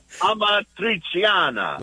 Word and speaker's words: Amatriciana: Well, I'll give you the way I would say it and most normal people. Amatriciana.